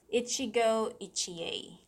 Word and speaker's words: Ichigo Ichie: First, it is again pronounced as Ichigo Ichie.